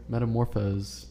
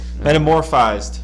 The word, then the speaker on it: metamorphosed: Metamorphosed. I don't even know if that's how you pronounce it. Metamorphosed. Metamorphosed. – Metamorphized. – No, there's no I.